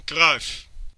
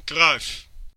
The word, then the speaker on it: Cruyff: Johan Cruyff. Johan Cruyff. – Där har vi det korrekta uttalet tydligen.